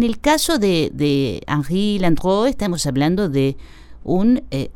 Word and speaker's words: Landru: Hablamos de Henri Désiré Landreau. Landreau que se escribe Landru